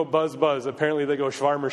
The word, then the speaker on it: Schwärmer: The German word for enthusiasm that Luther used was Schwärmer. Am I pronouncing that right? Yeah. Schwärmer.